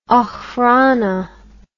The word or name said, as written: okhrana